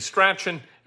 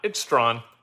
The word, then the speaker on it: Strahan: Of course, Mike Strawn's name is spelled STRAHAN. You'd think that that'd be Strachan. It's not. It's Strawn, of course.